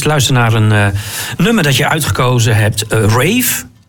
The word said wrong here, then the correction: Raven: zeg ik dat goed? – En, Raven, ja.